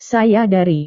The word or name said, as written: saya dari